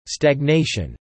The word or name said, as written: stagnation